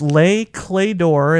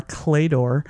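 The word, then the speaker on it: Les Clefs d'Or: But it's Le Clédor. It's spelled clay is the one that throws people. Clefs, D'OR, Clédor.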